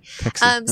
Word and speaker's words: Techsy: They're bringing Texi back, like tech. See? Tech? – Spell it. – T-E-C-H-S-Y. – S-Y. Okay. – Texi.